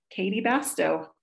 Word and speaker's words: Katy Bastow: It's just my first and last name, Katy Bastow.